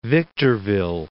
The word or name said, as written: Victorville